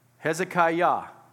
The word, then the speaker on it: Hezekiah: Well, I want you to notice that suffix, I-A-H, is pronounced Yah. Hezekiah, okay? So it's a reference to Yahweh.